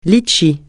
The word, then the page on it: litchi: francais